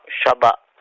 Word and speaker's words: Shaba: Chaba Radio, comment se prononce ? S-H-A-B-A, Chaba.